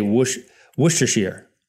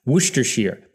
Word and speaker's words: Worcestershire: I say Worcestershire. Worcestershire. But I think it's Worcestershire. Worcestershire. It's got shire on the end. It's not sheer.